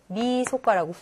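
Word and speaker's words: みそか: 미소카 31이니까 얘를 미소카라고 훈독을 하는 거야.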